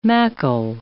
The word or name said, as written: macle